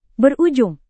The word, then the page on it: berujung: Indonesian